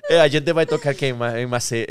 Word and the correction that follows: Maceió: Maceió e eles, não e eles falavam, repetiam, e, e eu nem sabia que eu estava falando errado.